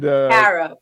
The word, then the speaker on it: Kara: And we're gonna come back and talk more about Kara's. Did I say it right? – Yeah, you did.